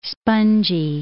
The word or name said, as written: spongy